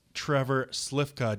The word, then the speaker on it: Trevor Slifka: And coming up next, we have a special guest, Trevor Slifka.